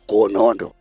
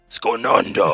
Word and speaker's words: Skonondo: You must call it like this. Skonondo. – Skonondo.